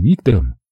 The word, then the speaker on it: Виктор: Небольшую комнатушку в приспособленном под жилье стальном морском контейнере он делил с капитаном-переводчиком Виктором, который, представляясь, сделал ударение на последний слог «Виктор».